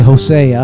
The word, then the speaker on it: Hosea: But the first passage says, and this is from the book of, depending on who you are, how you pronounce it, there's actually several correct ways of pronouncing it. Hosea, any one of them is correct. So I'll just say Hosea.